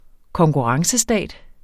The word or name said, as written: konkurrencestat